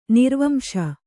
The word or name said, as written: ನಿರ್ವಂಶ